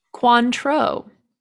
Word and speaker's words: Cointreau: Cointreau. It's an orange liqueur. – Conture. – I've been told. – That's how the French say it.